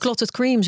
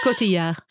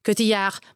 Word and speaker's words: Cotillard: Clotted Cream zeggen ze wel eens, maar het is Cotillard. – Cotillard.